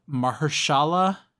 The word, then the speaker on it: Mahershala: He is voiced by, uh, and I'm gonna butcher this, uh, Mahershala.